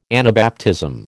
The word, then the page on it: Anabaptism: American English